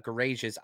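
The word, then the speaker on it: Gouraige: For me, let me read Richard Garage's. I liked him. I really did. Richard Garage. It's a soft G.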